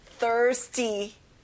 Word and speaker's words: thirsty: 목이 마른, thirsty. 자, R 발음을 정확히 해주세요.